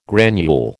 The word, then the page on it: granule: American English